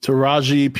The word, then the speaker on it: Taraji: And how do you pronounce her name? Taraji? – Taraji P. Henson.